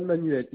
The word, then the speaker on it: Manuel: Johan Manuel, YOHAN.